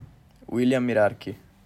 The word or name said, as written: Willy Mirarchi